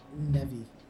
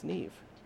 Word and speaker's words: Neve: No, listen to anybody say her name. It's Neve. It's Neve. Neve.